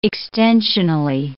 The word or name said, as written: extensionally